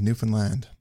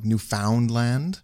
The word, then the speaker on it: Newfoundland: When you emphasize the found, like Newfoundland. – Yeah. – Or when you pronounce it more like Finland, you know, like the country Newfoundland. It's like, it's not Newfoundland, it's Newfoundland.